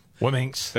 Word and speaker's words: Womxn: It's for students who self-identify as multicultural, Asian, South Asian, Black, African American, Indigenous, Native American, Latinx, Wiminx. I don't know how you pronounce that. – Wiminx. – W-O-M-X-N. Wiminx.